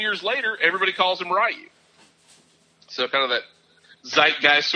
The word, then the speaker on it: Ryu: Everyone thinks that his name is Ryu. R-Y-U, one of the main characters in the video game. But apparently it's Ryu is the way it's pronounced. R-E-E-O-O, like Ryu, Ryu.